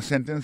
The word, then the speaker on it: sentence: Ça nous t'a dit dans sentence, c'est que accent tombe sous scène, sous premier syllabe là.